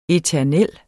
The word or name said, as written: eternel